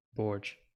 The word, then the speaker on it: Borg: Borg.